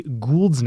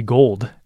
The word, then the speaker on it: Gould: Hey, listeners, I realized I mispronounced Jay Gould's name as Jay Gould. It is Gould, though he probably did have a lot of gold.